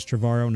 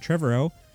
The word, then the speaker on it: Trevorrow: Now, apparently he does say his name Trevorrow. I feel like I'm being pretentious the same way when I say Scorsese, but it is Trevorrow, not Trevorrow.